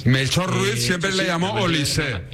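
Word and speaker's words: Olise: Oye, el otro día tuve una agria polémica con los compañeros porque tú me garantizaste que se pronuncia Olís y me pusieron cortes jugador que dijo que es Olisé. – No lo sabía, me extrañaba. No, efectivamente se dice Olisé,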